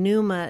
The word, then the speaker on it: Pneuma: And Pneuma is spelled Pneuma. And if you think of pneuma, think of pneumonia.